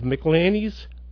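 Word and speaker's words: McIlhenny: Here to help us find an answer, we have Jeffrey Rothfeder, who is the author of McLanny's Gold. Did I get the name right? – Yeah, that's correct. Thank you.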